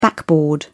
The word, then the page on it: backboard: British English